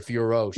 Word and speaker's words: Fiorot: This girl that she's fighting, um, I don't want to mispronounce her name, so I'm going to read it. Uh, in Fiorot, I believe that's how you pronounce yeah. her. Fiorot, she's yes. French. Fiorot. Yeah.